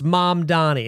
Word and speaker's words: Mamdani: I was interviewing Zohran Mamdani, and I just watched like the debate, his debate thing with Cuomo, when he's like, "Here's how you say my name. It's Mamdani," and he like really is laying into him.